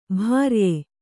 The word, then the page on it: ಭಾರ್ಯೆ: bhārye